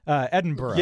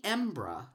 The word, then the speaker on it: Edinburgh: So how do you pronounce it? Do you pronounce it like Pittsburgh? – Yes, much like Pittsburgh. Edinburgh. – Yes. – It's not Edinburgh or Edinburgh, right? Edinburgh. – But actually, it's more often bruh, not burrah. – Edinburgh. – I found. – Okay. Uh-huh. Mm-hmm. – And it can even be Embrah.